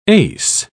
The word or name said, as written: ace